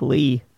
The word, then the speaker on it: Leigh: In fairness, it's spelled L-E-I-G-H, so it could be Lee or Lay.